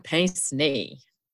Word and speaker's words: pince-nez: You don't have to say it with a French accent. The adventure of the golden pince-nez.